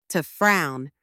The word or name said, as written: to frown